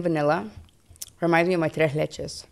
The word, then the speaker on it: tres leches: Give me a little lesson on the pronunciation. – Tres leches. – Say it again. – Tres leches. – Tres leches. – Leches. Leche. L-E-C-H-E-S.